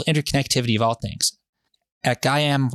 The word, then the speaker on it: Gaiam: Our name, Gaiam, pronounced Gaiam, is a fusion of Gaia, the name of the ancient Greek Mother Earth goddess, and I am.